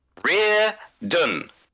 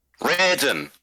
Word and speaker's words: Reardon: It is Reardon. Reardon. That's it. Two syllables. Not three, not four. Just two syllables. Reardon. Just, if you've just, that's it. That is how you say my fucking name.